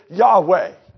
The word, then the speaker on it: Yahweh: The Hebrews would have pronounced it Yahweh.